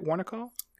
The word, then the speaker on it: Warnaco: Did I say that right? Warnaco? – Yeah, that, you said that right.